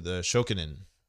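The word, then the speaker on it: Shokunin (職人): The Shokunin.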